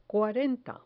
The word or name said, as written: cuarenta